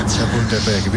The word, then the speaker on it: Chapultepec: A ver si lo pronuncio del todo bien. Chapultepec. Mejor que algún cementerio sí, francés. – Que... Chapultepec, bien.